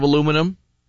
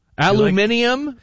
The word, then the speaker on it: aluminium: I did see though, we were talking about this last night at home, Kelly and I, the pronunciations of aluminum. How we pronounce it different here in North America than they do in Great Britain. I'm thinking of changing. I don't know. What do you think, Steve? Aluminium versus aluminum? – It sounds pretentious.